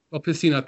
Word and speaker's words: Pessina: Look at Messina. Messina's been flourishing this season. Pessina, thank you.